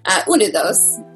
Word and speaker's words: Unidos: which is called Unidos. I called it Unidos the whole time. Didn't know it was a Spanish word. Unidos is how you say it.